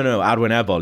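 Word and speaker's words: arwynebol: And he was like, how do you say that? Achwen Ebal. And I went, no, Arwen Ebal.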